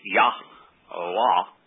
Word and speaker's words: Yahweh: While there is a 0% chance that the Hayes in Yahweh's name are pronounced yeh, eh, because all you have to do is look at the verb upon which Yahweh was based by his own presentation. – Like Hayah, you mean? – Hayah. And then look at Hebrew words with the hey in it, and the ah sound in Yah-Wah is certain.